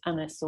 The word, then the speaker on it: Agnes: And he refers to her as my daughter, Agnes, which would have been pronounced in Elizabethan times as Annis or Agnes, a bit closer to the French.